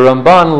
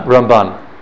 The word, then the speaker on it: Ramban: You need to have Ramban. – Yeah. You'll be able to tell Rambam that it's in Mele'el, like the first paragraph, first syllable is accented. Rambam. And Ramban, it's the second syllable. Milara.